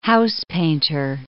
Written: housepainter

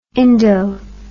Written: indo

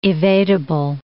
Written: evadable

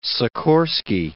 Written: Sikorski

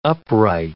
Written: upright

